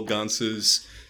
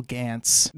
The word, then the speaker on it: Gance: I've been saying Abel Gance. – No, I mean, he's unfortunately quite French, so it's Gance.